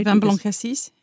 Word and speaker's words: vin blanc cassis: Vin Blanc Cassis.